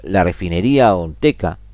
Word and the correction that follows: Olmeca: perdón, Olmeca, lo pronuncié mal,